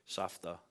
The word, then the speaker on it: Safta: That's Yiddish or Safta is how you say it in Hebrew.